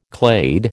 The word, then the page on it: clade: American English